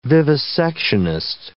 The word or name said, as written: vivisectionist